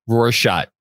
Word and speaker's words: Rorschach: Is it the Rorschach test? Is that how you pronounce that? Rorschach? The thing where it's like the ink blots? – Yeah, it's a Rorschach.